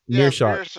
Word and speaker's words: Meerschaert: Meerschaert or what is Meerschaert? – Meerschaert.